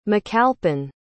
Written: macalpin

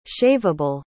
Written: shaveable

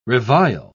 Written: revile

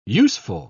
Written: useful

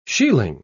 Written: shieling

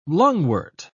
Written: lungwort

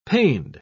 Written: pained

